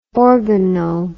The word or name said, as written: organo